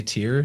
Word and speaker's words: Tyr: We say Tyr, T-I-R. It's phonetically looks a lot like the word that's spelled T-Y-R-E, which is a so-called Phoenician word.